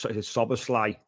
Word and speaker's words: Szoboszlai: It's Szoboszlai.